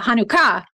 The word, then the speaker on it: Hanukkah: and the emphasis on the ah, Hanukkah.